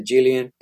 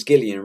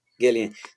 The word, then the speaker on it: Gillian: Yeah, sometimes I say Gillian, sometimes I say Jillian, but it's Gillian, right? – Gillian. – So, yeah. So, since I'm Latino, I say Gillian a lot of times, but it's Gillian in English.